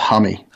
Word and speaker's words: Hume: It's called pronounced Hummy. – Hummy.